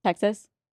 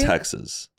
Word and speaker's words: Texas: Matt says Texas with a Z at the end. – Well, we also say like one syllable. – Say it. – Texas. – Texas. – Two syllables. – But I know, but it's like Texas. – And he's saying Z, and we say Texas with an Texas. – S at the end.